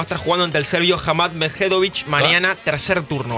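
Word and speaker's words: Hamad Međedović: Va a estar jugando con el serbio Hamad Medvedović. – Qué bien, qué bien que pronuncia. – Y lo repite perfecto las dos veces. Yo quería ver si pisaba, pero no.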